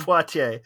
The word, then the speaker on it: Poitier: Poitier. – Poitier. Yeah, I figured, I figured it was French name and yeah, so yeah, Davis threatened to sue Harrison over it.